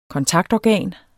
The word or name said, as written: kontaktorgan